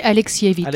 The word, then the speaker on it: Alexievitch: On dit Alexievitch.